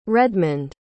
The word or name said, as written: redmond